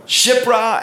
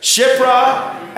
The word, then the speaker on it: Shiprah: The emphasis is on the end, on the awe. All right, say it with me. Shiprah, Puah. Shiprah and Puah. Say it together. Shiprah and Puah.